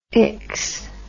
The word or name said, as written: ics